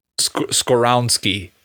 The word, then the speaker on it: Skoronski: Skoronski, I think, is how you pronounce it.